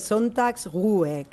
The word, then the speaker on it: Sonntagsruhe: A ver, el Sonntagsruhe, es una palabra difícil de pronunciar aún así, es Sonntagsruhe, que al final es descanso dominical, básicamente.